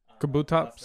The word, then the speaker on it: Kabutops: Yeah, Kabutops.